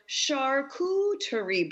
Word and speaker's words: charcuterie: looking for new ideas for charcuterie boards. – Oh, yes. – Sherry was really good about explaining the whole, how it's pronounced, which is S-H-A-R-C-O-O-T-E-R-I-E, charcuterie, because a lot of people mess that up.